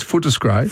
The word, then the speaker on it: Footscray: I like Footscray as a name. – Yeah. No, pronounce it properly. It's Footscray. – Footscray.